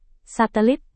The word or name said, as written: satelit